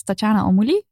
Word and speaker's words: Tatjana Almouli: Tatjana Almouli, zeg ik dat goed, op Instagram? – Ja.